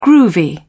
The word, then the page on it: groovy: British English